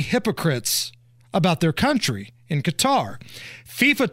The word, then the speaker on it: Qatar: I'm going to say Qatar.